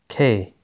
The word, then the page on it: K: Inglês